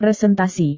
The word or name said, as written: presentasi